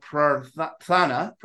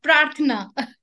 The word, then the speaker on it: Prarthana: And it's a K-12 school called Prarthana. Am I pronouncing that right? – Kind of. Prarthana.